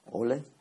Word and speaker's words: Hölle: Hölle. – Hölle. – Hölle. – Hölle. – Hölle. Hölle. What is it? – Hölle. – Hölle. Hölle. – Hölle. – Hölle. Hölle. Hölle.